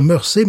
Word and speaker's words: Mersey: s'écrit Mersey, mais on dit Mersey parce, qu'on est anglais, comprenez-vous. Alors Glenafric, ça s'écrit G L E N plus loin, Afrique, A-F-F-R-I-C.